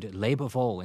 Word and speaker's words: Lebewohl: And these words are used in the title of the sonata, and in fact, the opening notes of the sonata even have the word Lebewohl in three syllables, farewell, written under them.